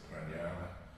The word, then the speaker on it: Pranayama: The whole reason I came across you was I sat with you and done a class with Pranayama.